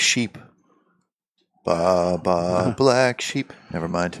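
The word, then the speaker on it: ba (八): It is pronounced ba in Chinese.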